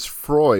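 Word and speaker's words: Froy: My name's Miss Frye. And she's like, Freud? Yes. It's like, no, Freud? No, Frye. – Rhymes with joy.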